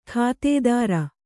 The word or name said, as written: ಗುತ್ತಾದಾರ